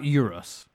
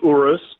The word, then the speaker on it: Urus: So it's Urus, right? Not Urus. – It is Urus, and Lamborghini has classically, with one or two exceptions, always named their cars after famous fighting bulls or lineage of bulls.